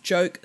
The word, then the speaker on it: Joque: It's the Spare Parts Joke Strap-On Harness. I believe it's pronounced Joke, J-O-Q-U-E. Joke could be jock, I guess.